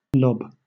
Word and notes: lob: British English

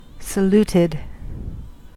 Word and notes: saluted: American English

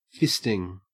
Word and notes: fisting: Australian English

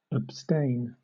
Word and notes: abstain: British English

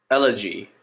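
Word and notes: elegy: Canadian English

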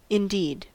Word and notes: indeed: American English

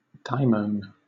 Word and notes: daimon: British English

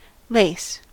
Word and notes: lace: American English

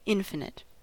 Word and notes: infinite: American English

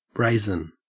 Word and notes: brazen: Australian English

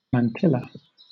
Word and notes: mantilla: British English

